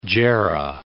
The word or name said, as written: jarrah